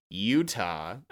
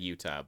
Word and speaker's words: Yuta: Marek and her assistant, Utah, arrive on the ship to help. Literally said Utah, but spelled Y-U-T-A, in case anyone was wondering.